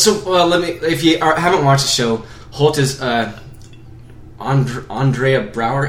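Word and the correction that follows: Andre Braugher: How do you say his name? – Andre Brower. – Andre. Okay, I was really close. I just gave him a feminine extra syllable.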